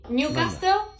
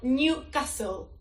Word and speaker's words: Newcastle: Newcastle... No, Newcastle.